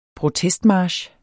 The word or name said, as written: protestmarch